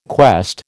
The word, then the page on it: quest: American English